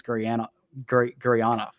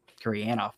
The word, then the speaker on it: Gurianov: But the two guys that I absolutely love watching are Roope Hintz and Denis Gurianov. Excuse me, Gurianov. That's not how they say things in Soviet Russia.